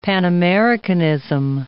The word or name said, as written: Pan-Americanism